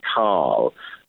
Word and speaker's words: Kahl: But there's also uh, a good friend of his called Colin Kahl, pronounced K A H L,